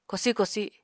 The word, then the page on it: kosi kosi: Apatani